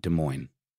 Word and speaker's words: Des Moines: And he, I'm sorry, Des Moines. I said Des Moines, and I got roasted for that before. Let me make sure I say it right. Des Moines.